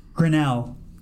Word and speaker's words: Grinnell: Grinnell.